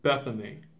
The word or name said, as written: Bethany